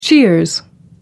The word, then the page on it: cheers: American English